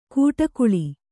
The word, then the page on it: ಕೂಟಕುಳಿ: kūṭakuḷi